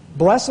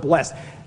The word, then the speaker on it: blessed: I never know how to use the word blessed. I originally came from Pennsylvania, and blessed is one syllable. I moved to Texas, and it's two syllables, blessed. I moved to Tennessee, it's three syllables, it's blessed. So every time I say blessed, it comes out in a different way because I've lived different places.